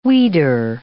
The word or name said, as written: weeder